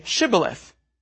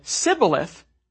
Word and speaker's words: Shibboleth: If he said no, they then said, "Very well, say Shibboleth." If anyone said Sibboleth, because he could not pronounce it, then they would seize him and kill him by the fords of the Jordan. 42,000 Ephraimites were killed on this occasion. So because the Ephraimites could not pronounce the phoneme sh in Shibboleth, this was the perfect test word or password.